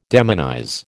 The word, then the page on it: demonize: American English